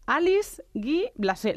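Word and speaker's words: Alice Guy-Blaché: Alice Guy Blasé.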